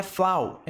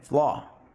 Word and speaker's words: flaw: Pronúncia não é flaw, é flaw.